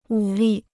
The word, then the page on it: 武力: wǔ lì